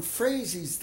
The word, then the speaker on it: Froese: In Hanley, Saskatchewan, there was some Froese's there, although I pronounced it Froese, because that's the way we pronounced it up there.